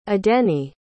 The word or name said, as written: adeniyi